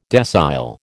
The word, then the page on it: decile: American English